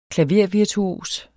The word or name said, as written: klavervirtuos